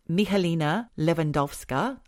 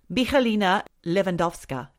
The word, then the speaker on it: Michalina Lewandowska: It's about a lady called Michalina Lewandowska. I think I said that right because my Polish daughter-in-law told me how to say that. Mm-hmm. Michalina Lewandowska, age 27.